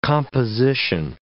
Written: composition

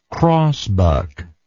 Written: crossbuck

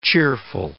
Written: cheerful

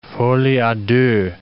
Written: folie a deux

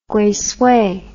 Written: guisui

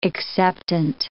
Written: acceptant

